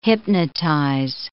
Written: hypnotize

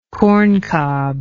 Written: corncob

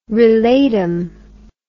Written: relatum

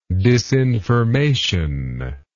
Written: disinformative

